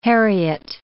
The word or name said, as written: heriot